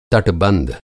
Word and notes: तटबंध: Hindi

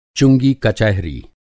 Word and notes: चुंगी-कचहरी: Hindi

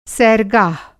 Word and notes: सैर-गाह: Hindi

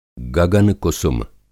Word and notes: गगन-कुसुम: Hindi

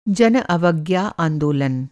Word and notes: जन-अवज्ञा आंदोलन: Hindi

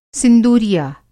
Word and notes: सिंदूरिया: Hindi

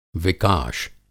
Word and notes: विकाश: Hindi

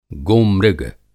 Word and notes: गोमृग: Hindi

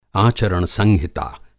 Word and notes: आचरण-संहिता: Hindi

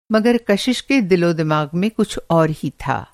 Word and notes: दिलो-दिमाग़: دل و دماغ

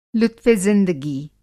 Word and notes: लुत्फ़-ए-ज़िन्दगी: لطفِ زندگی

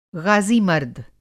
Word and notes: ग़ाज़ी-मर्द: غازی مَرد

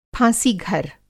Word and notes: फाँसीघर: Hindi

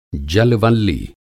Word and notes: जलवल्ली: Hindi

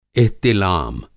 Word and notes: इहतिलाम: Hindi

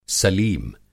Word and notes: सलीम: سلیم

